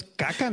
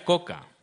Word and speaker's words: kaka: Esto es caca, ¿no? Es caca, caca, caca. – Sí, pero no. – si lo lees como español, dices caca. Eh, se pronuncia coca.